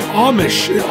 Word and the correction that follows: Amash: How's it pronounced? – I say Amash. I might be wrong, though. – Amash. Justin Amish. Amash.